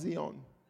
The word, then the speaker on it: Zion: And technically, that's not even the way to pronounce the word Zion. It's supposed to be pronounced Zion.